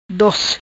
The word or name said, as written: Dos